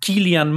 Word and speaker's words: Cillian: Dato muy aleatorio, me encantó verlo a Cillian Murphy, que aparentemente se dice así, no Cillian. – Se dice Cillian, sí, muy loco.